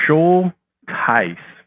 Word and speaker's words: Schultheiss: So I think it'd be great if you kind of share the Jonathan, how do we say your last name? Schulteis? – Schulteis. – Schulteis. Okay, there we go.